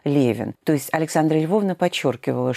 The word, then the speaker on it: Левин: И поэтому, вероятно, возникло предположение, что из-за того, что какими-то чертами своей судьбы, своей жизни, своего характера Левин напоминал автора Анны Карениной, то, вероятно, им казалось логичным произносить имя этого героя не как Левин, а как Левин.